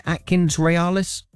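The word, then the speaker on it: AtkinsRéalis: Have I said that right? Is that right, Atkins Realis? – That's perfect. Yeah, absolutely perfect.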